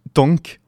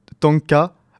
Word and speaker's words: Tangk: Maintenant, il est temps pour nous de voir son compte exactement la même chose, avec le nouvel album de Idols nommé Tank ou Tanka, bon, T-A-N-G-K, que je vais prononcer Tank parce que c'est rigolo.